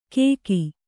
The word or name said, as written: ಕೇಕಿ